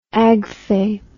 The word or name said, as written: agfay